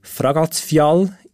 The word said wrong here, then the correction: Fagradalsfjall: Ja, ich habe es falsch ausgesprochen. – Ja, knapp. Also ich bin auch nicht perfekt isländisch. Eigentlich sehr wenig, aber heißt so viel, ich weiß, Fagradalsfjall.